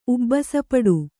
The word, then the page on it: ಉಬ್ಬಸಪಡು: ubbasa paḍu